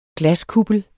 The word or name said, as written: glaskuppel